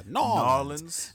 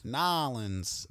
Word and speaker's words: NOLA: Uh, you, you, know, it got explained to me that it's uh, people say Nola. Yeah, the Nola. Oh, is is that is that how you pronounce? I, I it's spelled N O L A. – Yeah, Nola, not Nola. – Okay, it sounds like there's a Y in there.